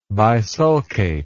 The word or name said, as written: bisulcate